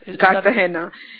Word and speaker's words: Cartagena: And the Colombian city whose pronunciation I keep getting wrong, Cartagena. – Cartagena.